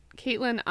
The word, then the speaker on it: Caitlin: Okay, you can follow me on Instagram and Twitter at Caitlin IR Foster, and Caitlin is spelled C-A-I-T-L-I-N.